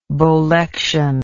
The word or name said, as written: bolection